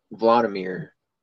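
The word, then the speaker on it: Wladimir: I think Vladimir, which is spelled with a W, but they pronounce your pronunciate it with a V.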